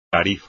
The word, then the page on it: tarifa: espagnol